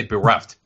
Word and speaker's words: bereft: He's befret ultimately of a religion. You know what I'm saying? – Bereft? – What did I say? Bereft. I'm sorry. I mispronounced it.